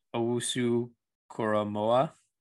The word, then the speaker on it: Owusu-Koramoah: Owusu-Koramoah.